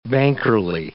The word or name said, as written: bankerly